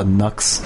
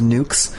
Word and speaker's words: Nux: Apparently, like, the pronunciation of his name changed the movie. Like, he introduces himself as Nukes, and at first it's Nukes, and then later on it's Nux.